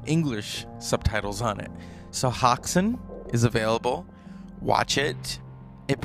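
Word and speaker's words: Häxan: Oxen. Let's make sure that I'm saying it right. Hoxen.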